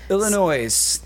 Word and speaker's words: Illinois: Illinois. The S is silent.